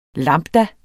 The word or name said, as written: lambda